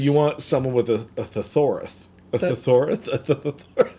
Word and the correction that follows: thesaurus: Am I saying that right? – Thesaurus. Nope.